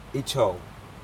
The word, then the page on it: ijou: Aneityum